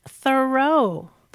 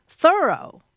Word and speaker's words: Thoreau: All this time, I thought his name was pronounced Thoreau, but no. – How is it pronounced then? – Thoreau. – Like I thoroughly did it. – Yeah, or I'm thoroughly surprised that it's pronounced that way.